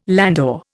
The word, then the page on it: landau: Indian